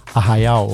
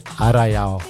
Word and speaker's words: Arraial: So we can be referred as Arraial or Arraiá, depends where, or Arraiá, depends which part of Brazil you are, right? – Yeah. – How do you say where you are from? – We say Arraiá. – Arraiá. Like in the south, like in the countryside, we say Arraial.